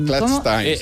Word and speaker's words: clutch time: ¿Clutch time o clutch time? – Clutch time, se debería pronunciar, perdón.